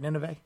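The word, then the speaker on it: Ninove: That's how you say it, right? Nineveh? – That's right, yeah.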